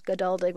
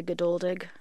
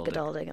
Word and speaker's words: Geduldig: Yeah, it's the Americanized as Geduldig, but it's really Geduldig. – Geduldig. – My parents say it wrong. They Geduldig. – Say Geduldig, and I learned later in life that it was pronounced Geduldig.